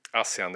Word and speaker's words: ASEAN: Vad betyder egentligen ASEAN? Var det bra uttalat av mig? ASEAN, det var bra uttalat.